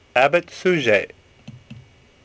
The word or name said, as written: Suger